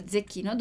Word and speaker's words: zecchino: Atenção aqui, a pronúncia do C, della doppia T, zecchino.